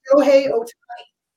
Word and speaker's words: Shohei Ohtani: I can't say his name. He's the baseball. – Shohei Ohtani. – Shohei Ohtani, yes.